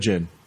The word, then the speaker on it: Djinn: All right, movie Djinn, because the Djinn. – Because the D is silent.